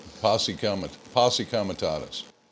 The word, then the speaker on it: posse comitatus: So I seen, how do you say it, posse comitose? – Posse comitatus.